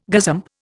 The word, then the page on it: gazump: Indian